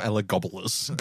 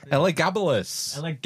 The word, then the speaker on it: Elagabalus: It's not Ella Gabalus. Gobble you up. Elagabalus.